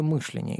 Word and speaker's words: мышление: Он неправильно ставил ударение, новое мышление.